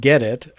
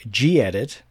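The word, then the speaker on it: gedit: Well, even though I use KDE and, as you pronounce it, Get-It, I pronounce it G-Edit. I don't know which is right.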